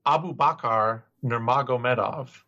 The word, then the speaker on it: Nurmagomedov: Abubakar Nurmagomedov. Abubakar Nurmagomedov. I'll put stresses on the right syllable.